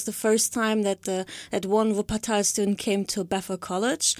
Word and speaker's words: Wuppertal: Wuppertal. – Wuppertal, okay.